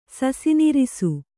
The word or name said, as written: ಸಸಿನಿರಿಸು